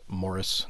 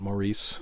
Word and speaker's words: Maurice: Speaking of, I think it's actually pronounced Morris, not Maurice, mm-hmm.